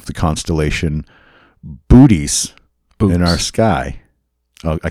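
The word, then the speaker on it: Boötes: It's pronounced Bootes.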